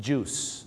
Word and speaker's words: juice: juice นะครับผม juice ไม่ได้ออกเสียงว่าจุ้ยนะฮะ juice นะครับผมท้องเสีย juice juice เลยนะครับผมน้ำผลไม้ไม่ใช่จุ้ยนะฮะต้องออกเสียงว่า juice นั่นเอง